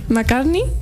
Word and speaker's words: McCartney: McCartney.